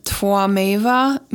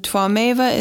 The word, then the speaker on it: Tvameva: And Twameva is spelled T-W, or actually I think it's technically T-V-A-M-E-V-A. And then I'm not sure, I think I'm doing it close to correct, but it's Twa. It's kind of like a W and a V sound together.